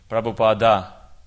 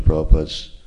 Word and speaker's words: Prabhupada: Why when we are singing, when we are glorifying Prabhupada, we are chanting Prabhupada and not chanting his whole name, Prabhupada. – The thing is that in Bengali, people often drop the last A. It's a silent A.